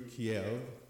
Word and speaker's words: Kiev: We flew in through Kiev, and that's the right pronunciation.